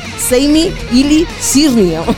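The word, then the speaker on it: Sami Yli-Sirniö: a ver si soy capaz de pronunciar esto, Seimi Ili Sirnio. – Sí, muy bien lo has pronunciado.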